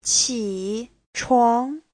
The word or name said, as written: qi3chuang2